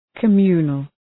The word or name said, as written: communal